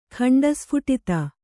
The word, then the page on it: ಖಂಡಸ್ಫುಟಿತ: kannada